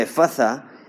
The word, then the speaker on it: Efatha: Ahora ese término, Efatha o Efatha, en el lenguaje original se pronuncia Efatha, es un término arameo que significa sea abierto.